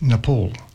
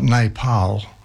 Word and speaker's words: Nepal: And yes, it's Nepal, not Nepal.